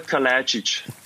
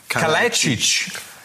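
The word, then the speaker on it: Kalajdžić: Wie spricht man deinen Nachnamen korrekt aus? Klär uns auf. Ja, es, es freut mich, dass ihr nach zwei Jahren endlich mal nachfragt. Na, Sascha Kalajdzic. – Kalajdzic. Okay, ja, also nicht genau.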